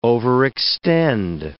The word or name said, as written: overextend